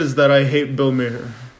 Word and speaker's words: Maher: Maher. It's Bill Maher. – Oh, it is. No, it's. Oh, it's spelled Maher with an H.